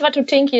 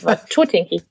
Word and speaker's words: Watutinki: War Tutinki und nicht war Betonung auf der 2. Nicht Watutinki, das ist, klingt nach Takatugala. Nein, es ja. ist Watutinki.